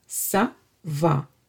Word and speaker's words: ça va: And whenever you see this C with this tail, you would pronounce it as S, ça va.